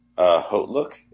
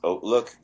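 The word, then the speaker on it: HauteLook: technically it's pronounced Oatlook, but they say Hotlook. I don't know. I think that's more for the people in this country that have to pronounce the H.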